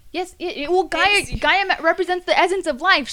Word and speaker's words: Gaia: It's spelled like Gaia, like the Mother Earth, whatever crap, you know, G-A-I-A. Yes, Gaia.